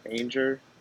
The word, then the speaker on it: Angier: Angier.